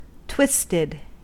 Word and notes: twisted: American English

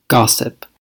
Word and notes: gossip: American English

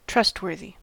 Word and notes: trustworthy: American English

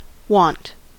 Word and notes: wont: American English

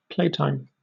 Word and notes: playtime: British English

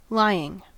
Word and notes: lying: American English

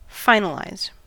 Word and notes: finalize: American English

